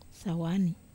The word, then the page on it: sawani: Wamesa